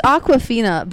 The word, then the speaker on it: Awkwafina: It's Awkwafina, but it's not spelled like the water.